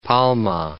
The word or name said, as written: Palma